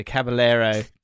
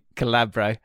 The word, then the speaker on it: Caballero: So Caballero, Calabro, Iheanacho, Itchy Nacho.